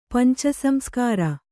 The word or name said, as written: ಪಂಚಸಂಸ್ಕಾರ